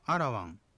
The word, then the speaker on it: アラワン: これは前の母音を弱く響かせた発音をしたり、そういった表記をしますが、まれにある、いる、うる、える、おると発音されることもあります。例えば、ナナという意味のアラワン、良いとかっていう意味のピリカというふうに、こういうふうに R は発音します。